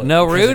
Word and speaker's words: Naruto: How do you say that? – Scratch that. Naruto. You want to say Naruto. – Naruto. – It's funny. – I watched Naruto. – And I agree. I say Naruto when I'm around big weebs because it makes them cringe.